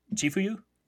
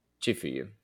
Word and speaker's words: Chifuyu: Chifuyu? – Chifuyu and Takamichi.